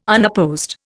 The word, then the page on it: unopposed: Indian